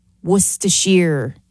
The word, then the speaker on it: Worcestershire: Worcestershire is how you pronounce it.